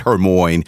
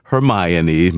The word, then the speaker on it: Hermione: And in Tuesday's celebrity birthdays, it was kindly brought up that I mispronounced actor Emma Watson's character in the Harry Potter movies. I said Hermione, and I haven't watched those movies in 20 years, but it's Hermione. My bad.